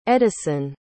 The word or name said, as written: eddison